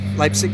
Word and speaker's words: Leipzig: Leipzig, but Leipzig.